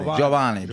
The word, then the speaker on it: Giovanni: Y-O-B-A-N-I. – Mm-hmm. – No, Y-O-B-A-N-Y. – Giovanni. – Giovanni. – Because, no, but it's not yo. You don't pronounce it. – It's Joe. Giovanni.